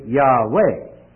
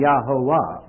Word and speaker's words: Yahweh: And Bible scholars, at least those to whom I refer, are agreed that it should be Yahweh rather than Yahowah, or as we know it, Jehovah. It should be Yahweh.